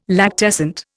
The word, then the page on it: lactescent: Indian